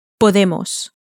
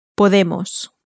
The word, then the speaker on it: podemos: Podemos. Podemos.